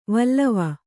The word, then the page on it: ವಲಿ: vali